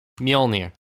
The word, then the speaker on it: Mjolnir: How do you say that? – Mjolnir. Like Mjolnir? Thor's hammer. – Oh my god. – How the fuck do you not know Mjolnir? – Mjolnir. – It's Thor's hammer. Thor's hammer.